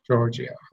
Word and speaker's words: Giorgio: Giorgio.